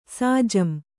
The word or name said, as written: ಸಾಜಂ